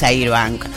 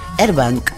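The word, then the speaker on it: Airbnb: No, no es Airbank, porque la A se pronuncia... ¿Cómo se pronuncia? Se cambia por la E, Airbank.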